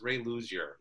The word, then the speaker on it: Ray Luzier: But did I say his name was Ray Luzier? No, it's Ray Luzier. That's his name.